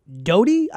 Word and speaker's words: Doughty: District Court Judge Terry Doughty, I believe is how you pronounce his name, D-O-U-G-H-T-Y,